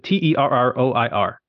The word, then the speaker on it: Terroir: First one is Terrorier. I don't even know how to say it. Terroir.